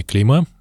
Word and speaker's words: клейма: номерами клейма, правильно, да, ударение ставлю? Да.